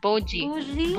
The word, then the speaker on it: bougie: The first word is Pujie. Pujee. Tama ba yung pagka-pronounce ko? – P-U? Hindi kaya Pudgy? – Pudgy ba? – Pudgy bar. – B ba? B? – B for B. A boy. B-O-U-J-E. – Bougie. – Bougie.